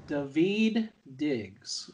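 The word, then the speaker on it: Daveed Diggs: David Diggs.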